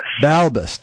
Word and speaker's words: Balbes: It's Xorin Balbes. Balbes.